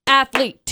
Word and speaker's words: athlete: Athlete, two syllables.